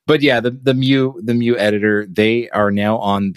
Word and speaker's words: Mu: And one of the great things, or one of the best things to pair with CircuitPython, is the Mu editor. I pronounce it Mu, sort of like the Greek letter. – Yeah. – Mu. I don't know if it's supposed to be Mu.